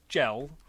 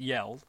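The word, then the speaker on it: Jelle: So for those of you at home, whoever you are that don't know about it, it's run by, I thought it was pronounced Gel, but it's not because it's a foreign name, so it's actually pronounced Yell.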